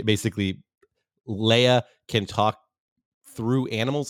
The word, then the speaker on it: Leah: Sorry. It's spelled Leah. Her name is Leia, because Charlie thinks about Princess Leia every time from Star Wars. – And every time that it's said, he thinks of it as Leia. But later we learn from what's her name, Dora, that it's spelled L-E-A-H.